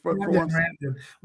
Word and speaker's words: Randon: Randon, right? Did I get his name right? – Randon. Like Brandon, but Randon.